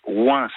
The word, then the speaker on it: Oingt: Il faut absolument que vous alliez dans le petit village de Ouin. Ça s'écrit O-I-N-G-T. Ouin.